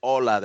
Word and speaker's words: hola: Hola, K-Dog, the world-famous DI and the founder of the Credit Control Network, Mr. That's hola. The H is silent.